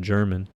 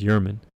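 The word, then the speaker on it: Germán: I believe a few episodes ago I pronounced it German because that's what it looks like, but I believe it is Yerman.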